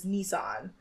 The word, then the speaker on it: Nissan: But the proper pronunciation is Nissan. – It's Nissan or Nissan? – Nissan. That's how the Japanese say it. But apparently other countries don't. It's like the only, apparently that's like a British Australian thing is to pronounce it Nissan.